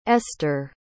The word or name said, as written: esther